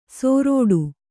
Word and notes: ಸೋರೋಡು: sōrōḍu

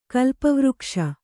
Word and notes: ಕಳ್ಪವೃಕ್ಷ: kaḷpavřkṣa

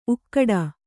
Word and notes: ಊಗಾಡು: kannada

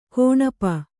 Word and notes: ಕೌಣಪ: kauṇapa

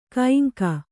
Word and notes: ಕೈಂಕ: Kannada

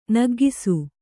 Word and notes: ನಗೌಕಸ: nagaukasa

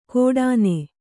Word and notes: ಕೋಡಾನೆ: kōḍāne

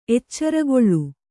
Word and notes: ಎಚ್ಚರುಗೇಳು: eccarugēḷu

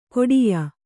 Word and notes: ಕೊಡಿಯ: koḍiya

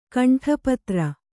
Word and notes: ಕಂಠಪತ್ರ: kaṇṭapatra

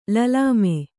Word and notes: ಲಲಾಮೆ: lalāme

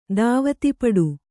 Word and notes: ಧಾವತಿಪಡು: kannada

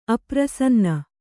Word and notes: ಅಪ್ರಸನ್ನ: aprasanna

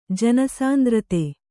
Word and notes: ಜನಸಾಂದ್ರತೆ: Kannada

